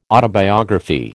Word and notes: autobiography: American English

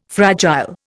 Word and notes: fragile: Indian

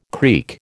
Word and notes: creek: American English

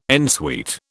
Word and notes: en suite: British English

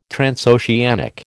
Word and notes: transoceanic: American English

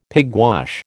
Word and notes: pigwash: American English

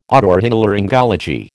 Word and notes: otorhinolaryngology: American English

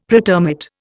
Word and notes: pretermit: Indian

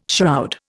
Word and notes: shroud: Indian

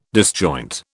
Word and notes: disjoint: British English

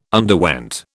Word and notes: underwent: British English

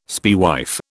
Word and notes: spaewife: British English

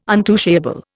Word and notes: untouchable: Indian

